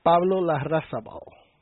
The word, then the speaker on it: Larrazabal: Pablo Larrazabal.